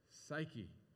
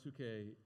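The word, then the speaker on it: psuche (ψυχή): Do you recognize the word? Psyche. Yeah, but it's pronounced psuche in Greek.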